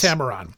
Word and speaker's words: Heptameron: The Heptameron. Did I say it properly? – Mm-hmm. Okay. – The yes. Heptameron.